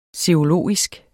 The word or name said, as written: serologisk